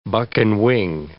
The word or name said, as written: buck-and-wing